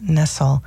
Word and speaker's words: Nestle: and it's spelled like the chocolate company, N E S T L E, pronounced Nessel.